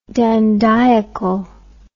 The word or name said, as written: dandiacally